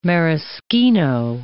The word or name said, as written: maraschino